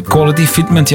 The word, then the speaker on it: Quality Fitment: Quality Fitment, zeg ik dat goed? Quality Fitment, ja, met Pets on the Road en Maddie's Mode.